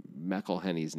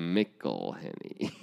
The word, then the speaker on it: McElhenney: Look at you pronouncing Rob McElhenney's name correctly. – Is that a problem for you? – No one pronounces that right. – How do you say it? – McElhenney.